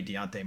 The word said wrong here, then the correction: Dejounte: Murray, Dejounte Murray. I can't say his name, Deontay.